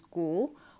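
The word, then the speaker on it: Goole: So the inspector introduces him as Ghoul, which obviously rhymes with G-H-O-U-L, like a ghost.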